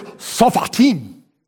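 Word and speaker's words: shoftim: And I said, you know, Mandy, the Greek word for, or the Hebrew word for judge is sophat. Ah, you must learn to pronounce. The word is sophatim.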